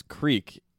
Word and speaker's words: creek: Yeah, but you don't like you don't pronounce creek. You don't pronounce it as creek when you're in Delaware. What? – You just pronounce it crick.